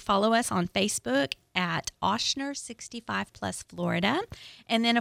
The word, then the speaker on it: Ochsner: Okay, yes, Oshner. – Get your pens out. – Yes, okay. – Get your pens out. – And it's Oshner is spelled OCHSNER.